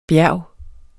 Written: bjerge

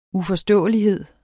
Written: uforståelighed

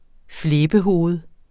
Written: flæbehoved

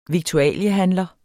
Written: viktualiehandler

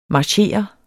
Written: marchere

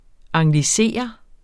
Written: anglisere

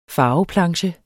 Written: farveplanche

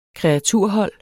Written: kreaturhold

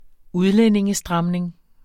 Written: udlændingestramning

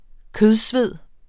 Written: kødsved

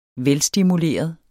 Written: velstimuleret

